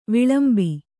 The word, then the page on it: ವಿಲಂಬಿ: kannada; vilambi